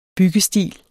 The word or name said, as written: BYGGESTIL